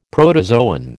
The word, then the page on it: protozoan: American English